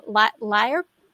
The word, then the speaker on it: lyre: I think they're looking for a lyre player. Is that how you pronounce it? The L-Y-R-E? – Yeah.